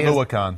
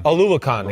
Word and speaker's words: Oluokun: I can't say Oluokun. – Oluokun. Excuse me. Yeah.